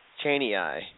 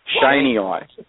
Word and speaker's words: chaneyi: Morelia spilota chaneyi. – Chaneyi. It's actually Shane. Shaney eye.